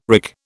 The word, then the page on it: wrick: British English